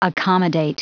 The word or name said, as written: accommodate